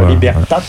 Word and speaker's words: Libertat: Je ne prononce pas le T à la fin de Libertat, mais je n'ai pas tout dit.